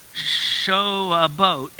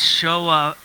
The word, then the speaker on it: Shavuot: It's not show a boat. It's Shavuot. Shavuot. Okay. I'm working on this. Shavuot. Shavuot. There we go. Everybody say that one time. Shavuot.